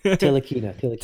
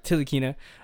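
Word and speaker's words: Ntilikina: Tilikina. – There it is. – Tilikina. Just don't pronounce the N. – All right. Tilikina.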